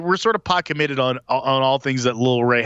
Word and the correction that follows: Lil Rel: Lil Rel. So we should get his name right if we're pot committed.